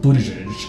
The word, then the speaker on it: Buttigieg: It's Buttigieg, and it's Buttigieg.